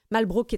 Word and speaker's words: Malbrook: Malbrook étant maintenant la prononciation universellement établie pour cette chanson sous l'influence de ce duc français.